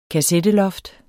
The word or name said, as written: kassetteloft